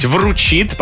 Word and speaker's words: вручит: Все-таки правильно говорить «вручит», поэтому здесь 1000 не зарабатывайте.